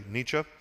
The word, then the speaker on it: Nietzsche: Sometimes Americans like to pronounce it Nietzsche, but I think the German is actually Nietzsche.